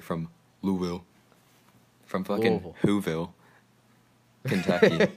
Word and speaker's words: Louisville: It's not Louisville. Louisville. – Louisville. – No, Louisville. – Okay. – Louisville. That's how they say it over there.